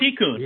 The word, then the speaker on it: Tikkun: I'm not sure if I'm saying this right. Tikkun, I think. – Tikkun, yeah. Okay.